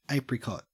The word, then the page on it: apricot: Australian English